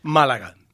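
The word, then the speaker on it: Málaga: Malaga. – Malaga. – Yeah. – So how close is Malaga to what, for our audience, it's close to some big city they would recognize? – Well, Malaga is in the south of Spain, totally right.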